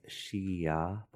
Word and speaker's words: Xia: So the kingdom is it's spelled X-I-A in the romanization, and it's pronounced Xia.